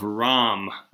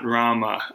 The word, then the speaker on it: Ram: Incidentally, it's the Epic of Ram, R-A-M, not Rama. We're using the Hindi... The Hindi pronunciation.